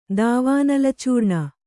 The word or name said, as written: ದಾವಾನಲಚೂರ್ಣ